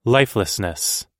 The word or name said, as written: lifelessness